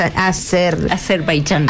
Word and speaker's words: Azerbaiyán: Azerbaiyán.